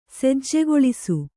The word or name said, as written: ಸಜ್ಜೆಗೊಳಿಸು